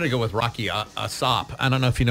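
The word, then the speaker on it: A$AP: His name's Asop. That's how he pronounces it.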